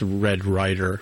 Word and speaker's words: Red Ryder: I mispronounced Red Ryder, which is what I meant to say.